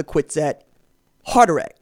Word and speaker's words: Kwisatz Haderach: Oh, but but, and then from there, you're supposed to get what the Bene Gesserit call the Kwisatz Haderach, or Haderach. Let me say that again. Kwisatz, Haderach.